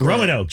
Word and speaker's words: Roanoke: How do you say Roanoke? Are you a Roanoke? – Roanoke, just the way you did.